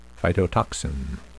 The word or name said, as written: phytotoxin